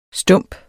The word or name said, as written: stump